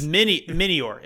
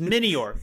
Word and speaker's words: Minior: Meteors were cool. The nighttime. – I thought that was super neat. I love some meteor. Meteor. That's how you say it? No, it's Minior. It's Minior. M-I-N-I-O-R.